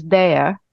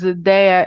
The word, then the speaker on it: Dea: And it was quite of a difficult approach for me to introduce that, because Dea is spelled DEA. And in US, right, it's kind of like a completely different meaning than a cute nickname.